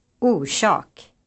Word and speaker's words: orsak: The rush sound is pronounced when the letter S follows an R, as in the words orsak, kosh, and losh.